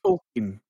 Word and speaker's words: Tolkien: I believe the pronunciation for the name is Tolkien. I believe his name is actually pronounced Tolkien, but the U.S. calls it Tolkien.